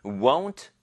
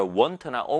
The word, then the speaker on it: won't: 이 won't를 won't 요렇게 끊어서 읽는다면 won't, 요거를 부드럽게 읽으면 won't가 되겠죠. 그런데 이거를 want나 won't나 여러 가지 창의적으로 읽는 친구들이 가끔가다 있습니다. 그래서 won't라고 읽으면 가장 좋을 것 같아요.